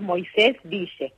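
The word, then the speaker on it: Moisés Ville: Sí, en realidad nosotros ahora pronunciamos Moisésville. Pero el origen es en hebreo y es Kiryat Moshe. Ese es el nombre original, Kiryat Moshe, que se lo denominó así al pueblo el rabino Aarón Halevi Goldman, que fue el jefe espiritual del primer grupo.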